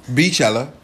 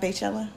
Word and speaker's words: Beychella: Oh, it's not Baychella? Beychella, okay. – And I was watching the news yesterday, and she said Baychella. I'm like, how do you get Bay? – Because it's spelled B-E-Y. – It's Beychella. – B-E-Y.